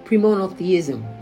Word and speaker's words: premonotheism: Christianity is deeply rooted in premonotheism, premonotheism, that's how to pronounce it, and pagan mysteries.